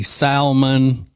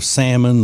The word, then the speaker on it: salmon: Let's see, salmon, saying that instead of salmon the way it's supposed to be pronounced.